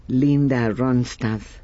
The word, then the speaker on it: Linda Ronstadt: Así que, y un día como hoy también nace Linda Ronstadt.